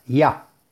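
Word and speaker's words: Yah: E, y, se refiere al santo nombre de Yud Hei, se pronuncia Ya, que son las dos primeras letras del tetragramatón.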